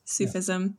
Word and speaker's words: Sufism: Sufism.